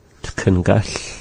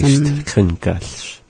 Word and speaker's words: Tkengal: How do you say socks? – Tkengal. – Yeah, I put on. – Tkengal. – I think they say stkengall. – I put on some stkengall today, and I don't want them on anymore.